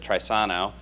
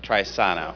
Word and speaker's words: Trisano: You heard me say it, but it's pronounced like this, Trisano.